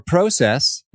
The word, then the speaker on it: process: but you have laid out a bit of process, or process, as Canadians say. – Do we say it different? – I love it. You do. It's a long O instead of a short O.